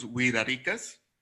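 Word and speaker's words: Wixárika: estabas hablando, la cultura es lo que nosotros llamamos la cultura huichol, o como se autodenominan los huiraricas, que hace poquito descubrí que sí. – Se escribe huixarica, pero la X se pronuncia como una R, como que un poquito más.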